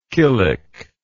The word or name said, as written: killick